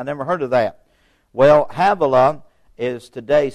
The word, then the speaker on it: Havilah: Havilah, that's pronounced H-A-V/A/L-A, Havilah, unto Sur, S-I-R, Sur, that is before Egypt, as thou goest towards Assyria, and he died in the presence of all his brethren.